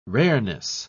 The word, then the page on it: rareness: British English